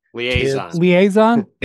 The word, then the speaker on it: liaison: He is the perfect liaison, and I think I'm pronouncing that word correctly, the perfect liaison in the locker room between Liaison. – I was close.